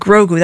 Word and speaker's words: Grogu: So let's play a little clip from The Mandalorian and Grogu. That's how you say it, right? – Yes.